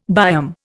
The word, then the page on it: biome: Indian